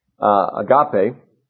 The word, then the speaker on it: agape: The word for love in the chapter, you probably also know, is the Greek word agape, or agape, really. The accent is on the second syllable, agape.